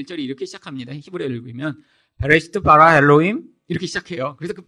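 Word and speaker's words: 베레시트 (בראשית): 자, 창세기는 원래 히브리어로 베레시트라고 읽습니다.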